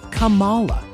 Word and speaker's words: Kamala: And despite what mainstream media would tell you, it's pronounced Kamala.